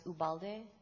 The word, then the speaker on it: Ubalde: It's my last name is spelled U as in under, B as in boy, A, L, D as in David, E, and it's pronounced Ubalde.